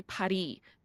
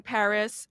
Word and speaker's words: Paris: even in France, they don't pronounce it Paris. They say Paris. They don't pronounce the S.